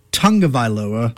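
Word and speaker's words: Tagovailoa: Small complaints to his last name is pronounced Tungavailoa.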